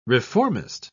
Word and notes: reformist: British English

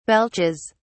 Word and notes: belches: British English

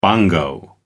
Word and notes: bongo: American English

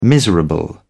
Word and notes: miserable: British English

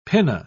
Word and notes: pinna: British English

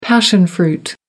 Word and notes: passion fruit: American English

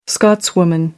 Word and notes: scotswoman: American English

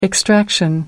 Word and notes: extraction: American English

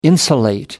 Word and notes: insulate: American English

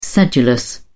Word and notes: sedulous: British English

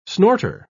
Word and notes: snorter: British English

